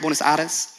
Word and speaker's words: Buenos Aires: Am I saying that right? Buenos Aires? Okay, don't come at me, okay? I like that, beautiful.